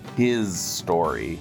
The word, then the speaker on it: HIStory: The album we're talking about today is Michael Jackson's penultimate album, History, Past, Present, and Future, Book One. – Well, you mispronounced that. It's his story.